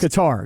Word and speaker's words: Qatar: It's like guitar. Qatar. There's like an accent on it. Qatar. And you're supposed to roll the R at the end.